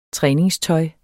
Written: træningstøj

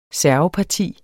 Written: serveparti

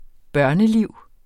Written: børneliv

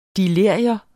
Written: delirium

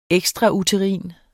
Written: ekstrauterin

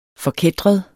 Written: forkætret

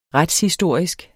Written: retshistorisk

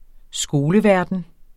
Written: skoleverden

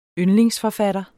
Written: yndlingsforfatter

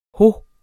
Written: ho

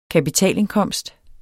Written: kapitalindkomst

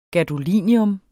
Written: gadolinium

